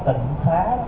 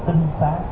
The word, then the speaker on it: tinh xá: Và bây giờ chúng ta đọc trại ra là tịnh xá, chứ đúng chữ là phải đọc là tinh xá.